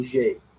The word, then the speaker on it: Niger: So commenting is some coup d'etat in Niger, so don't mispronounce that one. It's a soft G, not a hard G, so named because the river Niger bisects it.